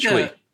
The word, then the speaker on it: Twi: Yeah, which is pronounced Chui.